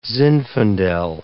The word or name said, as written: zinfandel